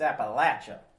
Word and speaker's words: Appalachia: I don't even know the right way to pronounce it technically, but culturally, it's Appalachia, like a latch.